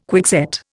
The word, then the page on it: quickset: Indian